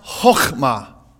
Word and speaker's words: chokmah: And the Hebrew word for wisdom is pronounced chokmah. You kind of hawk something up a little bit when you say that.